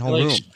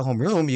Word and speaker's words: homeroom: I'm like, why are you calling it homeroom? – Stress the second part of the They're putting the wrong emphasis on the wrong syllable.